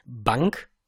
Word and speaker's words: bunq: Und das ist einmal Bank ausgesprochen, B-U-N-Q geschrieben, ist ein niederländisches Unternehmen, und einmal N26, Number 26, Nummer 26, wie auch immer ihr die nennen wollt.